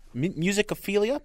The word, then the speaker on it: Musicophilia: I think the place to start as we dive into this new book, Musicophilia, is that the right way to say it? – Uh-huh.